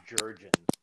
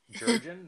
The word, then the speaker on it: Jerjian: Yeah, so it's Jerjian. – No, it isn't. – Yeah. – That's so easy to say. – Jerjian, yeah.